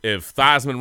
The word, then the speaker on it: Theismann: Look, if Theismann rhymes with Heisman, man, you can go with A-Chain, dude.